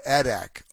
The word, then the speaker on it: Adak: Is it called Adak or Adak Island? – It's pronounced Adak, just A-D-A-K Island.